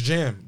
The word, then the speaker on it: Jamn: His name's Jam. Yeah. J-A-M-N. – Okay. – That's how you spell it. Like, damn. – Yeah. – Instead of the D, Jam.